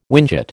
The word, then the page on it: whinchat: American English